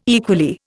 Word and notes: equally: Indian

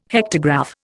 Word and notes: hectograph: Indian

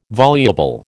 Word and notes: voluble: American English

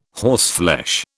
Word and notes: horseflesh: British English

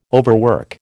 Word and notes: overwork: American English

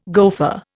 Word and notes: gofer: Indian

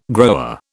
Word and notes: grower: British English

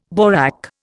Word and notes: borak: Indian

